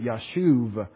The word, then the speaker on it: Yashub: Why'd I spell it Yashub, Y-A-S-H-U-B, and I pronounce it Yashuv?